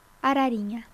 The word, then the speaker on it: Arariña: Arariña, dis-moi si je prononce bien. – Ça va. – Tu le dis, mais dis-le toi, moi j'aime bien. – Carlinhos Brown, Arariña.